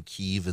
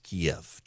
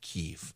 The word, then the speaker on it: Kyiv: So uh, the Russian pronunciation of the capital of Ukraine is Kiev, chicken Kiev, Kiev. Uh, the Ukrainian pronunciation is Kyiv, and I'm trying to say it the Ukrainian way in solidarity with what's happening in Ukraine.